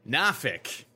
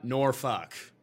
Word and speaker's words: Norfolk: This for UFC Nafik. I think that's how the locals say it. Norfolk, Nafik, definitely not Norfolk.